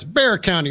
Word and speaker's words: Bexar: We're on like a 6-week backlog in Atlanta or Gwinnett County or Fulton County in Atlanta or Dallas or Bexar County. I always mispronounce it. – It's Bear County, baby.